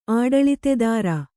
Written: ಆಡಳಿತೆದಾರ

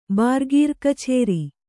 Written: ಬಾರ್ಗೀರ್‍ಕಛೇರಿ

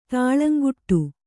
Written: ತುಳುಂಕಾಡು

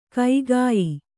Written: ಕಗ್ಗಾಯಿ

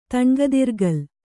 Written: ತಣ್ಗದಿರ್ಗಲ್

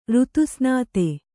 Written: ಋತುಸ್ನಾತೆ

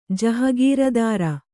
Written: ಜಹಗೀರದಾರ

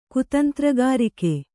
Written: ಕುತಂತ್ರಗಾರಿಕೆ

